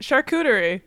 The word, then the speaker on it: charcuterie: Charcuterie? Why can't yeah. – I say that? – I think you did it. Charcuterie.